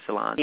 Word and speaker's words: Solange: Is that how you pronounce her name, Solange? – Yeah.